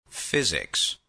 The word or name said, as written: physics